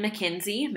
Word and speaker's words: McKenzie: Yeah, I am on Instagram as Griffin McKenzie.